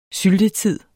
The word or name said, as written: syltetid